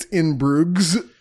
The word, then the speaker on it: Bruges: What the fuck is In Bruges? – I thought there was like, just shut up. – So phonetically, it's In Bruges.